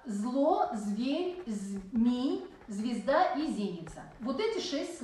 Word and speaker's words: зеница: Не зеница, а зеница. – Зеница. – По-русски мы говорим зеница, а по-церковно-славянски зеница. Расходится ударение. Зеница.